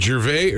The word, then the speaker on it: Gervais: How do you say his name? Is it Gervais? – Ricky Gervais. You got it right.